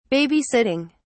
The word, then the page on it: babysitting: British English